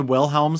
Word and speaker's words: Wilhelm: And then there's a character, Otis Wilhelm, which is funny because is that how you say it? The Wilhelm scream? – Yeah.